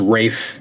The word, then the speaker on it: Ralph: And yes, it's Rafe, not Ralph Cody. – It's spelled R-A-L-P-H.